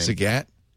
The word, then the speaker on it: Zagat: Zagat? Rhymes with cat, like the cat.